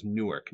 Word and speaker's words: Newark: I was in person, but no, it was Newark, not New York. – Gotcha. – Mm-hmm. Or as I say it, Newark. – It's Newark, yeah. – Newark. – Newark. – I say it like it's N-O-R-K.